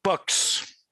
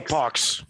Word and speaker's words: Pucks: Pox. C'est Pox. Le monde le prononce Pox, oui.